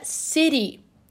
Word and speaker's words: city: Por exemplo, a palavra cidade em inglês, né, um, um americano, um estadunidense falaria city. City. Mas a palavra se escreve city, né? É C-I-T-Y. Por que, que ele fala city? City, como se fosse um R e não um T.